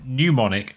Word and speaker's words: mnemonic: Let's put it out to the audience. How do you pronounce mnemonic? – You pronounce it mnemonic.